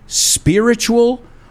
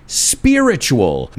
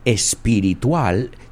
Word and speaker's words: spiritual: In modern English, a cult is a social group that is defined by its unusual religious, spiritual, or philosophical beliefs and rituals. So there's a lot of vocabulary right there. Unusual, poco normal, poco usual. Religious, fijaos en la pronunciación, spiritual, not espiritual, spiritual or philosophical beliefs.